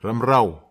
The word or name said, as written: ramrao